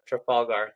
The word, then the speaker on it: Trafalgar: In your time kind of running Trafalgar, did I say that right the second time? – Mm-hmm. – Trafalgar, do you have any crazy success stories that stand out? – Oh gosh.